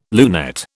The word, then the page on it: lunette: British English